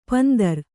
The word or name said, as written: ಪಂದರ